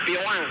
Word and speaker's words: Pizjuán: Porque es Sánchez Pizjuán. – Pizjuán. – No, a ver, vamos a pronunciarlo bien. Pizjuán.